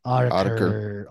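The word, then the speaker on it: Autechre: How do you say that shit? – Autiker.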